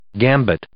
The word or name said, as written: gambit